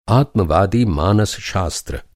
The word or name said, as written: आत्मवादी